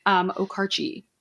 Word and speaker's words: Okarche: It's Okarche. It's O-K-A-R-C-H-E. I mean, Okarche. I really don't have to say it more than right now. So I'm just going to go with it there. Okarche.